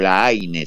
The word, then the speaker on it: La Haine: Katz tiene un blog que depende del sitio La Haine, o La Haine, si la pronunciamos en español.